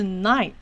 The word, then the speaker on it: night: 如果它在字中，这个是。不发音哦，这是叉叉不发音，尤其是跟 i 连在一起的时候是发 i 的音，所以 gh 是没有发音的，所以这个是 night night。